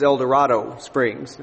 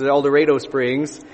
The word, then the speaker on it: El Dorado Springs: I said, "Please don't say that here. I grew up here. This is El Dorado Springs." Spelled like El Dorado, but not pronounced the same.